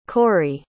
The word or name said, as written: Cori